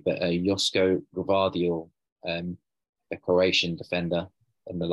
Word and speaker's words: Gvardiol: Yeah, Gvardiol, I think that's how you pronounce it.